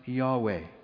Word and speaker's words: Yahweh: That being said, scholars think that it was likely pronounced Yahweh.